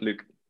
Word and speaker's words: Luc: It's no code, Luke. And my name is LUC. It's a French name. I'm Dutch, but it's a French name. It's kind of difficult to pronounce, but the spelling is pretty easy.